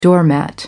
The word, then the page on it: doormat: American English